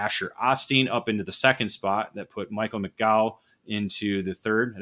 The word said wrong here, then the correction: McGoy: Did I say that right? – I think they say Michael McGoy. – McGoy, yes, okay.